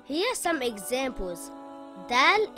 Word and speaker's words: Dal: Dal is a light letter and should not be pronounced heavy. Dal makes a similar sound in English to the letter D in the word dad or the word dark.